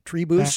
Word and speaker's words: tribus: Am I pronouncing that right, Kevin? Do you know? Tribus? – That sounds right to me.